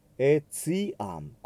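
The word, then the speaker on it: etiam: クルチフィクスス、エツイアン、こっちはチですね。 TI の後ろに A がついてますので、チではなくてチという発音になります。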